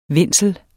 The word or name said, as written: vindsel